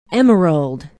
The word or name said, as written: emerald